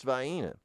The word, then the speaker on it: Vienna: It's spelled Vienna, but it's pronounced, I assure you, it's pronounced Vienna.